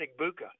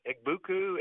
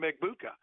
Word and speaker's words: Egbuka: I mean, but you got the press box announcer at Michigan State called him everything, called Emeka Egbuka, everything but Egbuka. Egbuku, Egbaku. I mean, he went around down the line, but he never called him Egbuka.